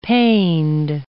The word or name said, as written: pained